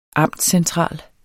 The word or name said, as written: amtscentral